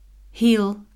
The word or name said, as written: heel